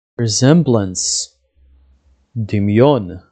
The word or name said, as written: resemblance